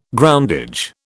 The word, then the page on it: groundage: British English